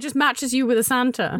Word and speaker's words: Santr: I should say for listeners, the app Santa is spelled S-A-N-T-R. Santa.